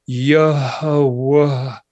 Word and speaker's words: Jehovah: Jehovah is really the sort of a way of pronouncing the Tetragrammaton, which is a yuh and then a huh, just like breathing, and then a wuh and then a huh. It almost sounds Hawaiian, you know, Yehovah.